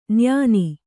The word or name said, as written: ನೈಯೆಣ್ಣೆ